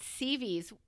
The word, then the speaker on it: CVS: And they said, what do you mean you checked CVs? Where's CVs? Oh, no, it's on the corner of Peachtree and blah, blah, blah. Spell that for me. CVS. Oh, honey, that's not CVs. That's CVS.